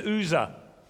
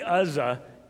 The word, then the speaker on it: Uzzah: And his name is, how did you pronounce it? Uzzah? Okay, it's Uzzah. It can be Uzzah. Either way is acceptable, but I'm going to try to go with Uzzah because I don't want to make Martha look bad.